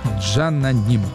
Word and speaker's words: cananım: Да, я вспомнил, что «Си» по-турецки читается как «Джа». «Джананим».